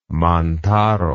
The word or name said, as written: mantaro